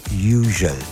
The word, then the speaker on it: Hugel: Lui è Florent Hugel, non è che un disc jockey di una radio inglese possa dire Hugel e tutti a ruota come le pecore, cioè abbiamo una dignità, facciamo questo mestiere a certi livelli, lo dovete pronunciare Hugel, con Moving to the Sun.